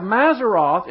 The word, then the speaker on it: Mazzaroth: So if you have Maseroth, in fact, you might just write, if you have a New American Standard Version, you might write Maseroth, it's spelled M-A-Z-Z-A-R-O-T-H.